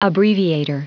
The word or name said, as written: abbreviator